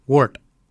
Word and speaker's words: woord: Yet is spelt Y-E-D. Yet. Yet is your chin. For throat, we say wort. For throat, we say wort. Wort is spelt W-O-O-R-D. Wort. And that was today's lesson.